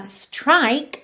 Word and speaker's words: strike: Uh, strike, atenção que o é final é mudo.